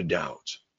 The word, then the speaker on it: doubt: Oh, sin duda, without a doubt. Notice that when I say it, the B is silent.